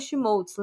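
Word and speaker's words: Schmolz: na Twitch no arroba ou barra Fernanda Schmoltz.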